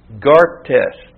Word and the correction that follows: gaster: garter, sorry. And that is spelled A-R-G-O-S, Argos, and Gaster, G-A-S-T-E-R, Argos, Gaster.